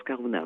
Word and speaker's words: Karuna: Elle s'appelle Karuna, on prononce Karuna, oui. – Karuna, voilà. Comme en basque finalement, puisque en basque aussi le U se prononce OU.